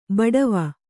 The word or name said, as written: ಬಾಡವ